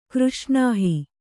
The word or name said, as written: ಕೃಷ್ಣಾಹಿ